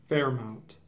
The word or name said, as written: Fairmount